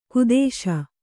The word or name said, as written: ಖೇದಿಸು